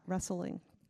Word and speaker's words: wrestling: Because it has a silent W, and silent W's are stupid, right? So we're going to get rid of the silent W because that's stupid. We don't need that. But it should be spelled like that, wrestling.